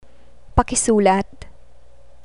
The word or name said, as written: pakisulat